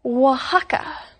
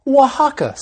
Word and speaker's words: Oaxaca: You see, it's spelled O-A-X-A-C-A, but it's pronounced Oaxaca. – That's right. Oaxaca.